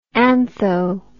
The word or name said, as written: antho